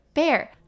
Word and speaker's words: bear: 那它跟 b a r e 这一个字呢，它的发音一模一样，都是微笑的嘴型 a。 Bear 指的就是光溜溜的、啊、像是没有穿衣服的。